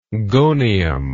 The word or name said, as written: gonium